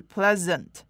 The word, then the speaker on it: pleasant: 那最后一个发音技巧我们要来看到的是 pleasant，要注意这边的 s 不是发 s 的声音，不是无声的吼，而是发 z，所以是 pleasant。